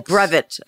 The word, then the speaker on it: brevet: Oh, brevet. Okay. – And that's a weird word. And what it is in the Sea organization. – Did I say it wrong? – No.